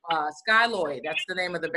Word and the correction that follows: Skjold: Yeah, it's pronounced Shold. It's a Nordic name. Swedish.